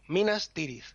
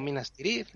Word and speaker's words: Minas Tirith: Incluso cuando los nombres son de varias palabras, de dos sílabas, lo mismo. Minas Tirith, Minas Morgul. No Minas Tirith, Minas Morgul, etc.